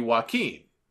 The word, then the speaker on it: Joaquin: I, it should be Joaquin, and he is the former DJZ from Impact.